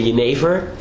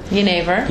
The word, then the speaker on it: Jenever: Yeah, it's in Holland, we say Jenever. – Jenever. – Jenever. And it was already written in the 17th century with a G, so for English to pronounce it, it would become Jenever.